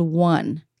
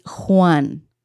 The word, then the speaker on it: Juan: The way to say his name is not Juan, like number one. It's Juan. So imagine what I try to say to English speakers is imagine there's a W and an H, like who, an, like you're asking a question.